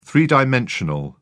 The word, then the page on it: three-dimensional: British English